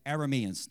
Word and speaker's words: Arameans: All right, and it says, and at this time the Arameans, what would the actual pronunciation of that be? The raiders. I want to make sure I say it right. Arameans.